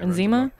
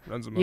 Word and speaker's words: Renzema: Is it Renzema? – Yeah, Renzema. Renzema.